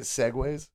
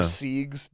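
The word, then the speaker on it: segue: Or siegs, depending on how good your pronunciation is. – Yes. – It depends on how literate you are. – Yeah. – Yeah. – If you pronounce it sieg, I have some really bad news for you. – You're it's so over. – It's not pronounced like that.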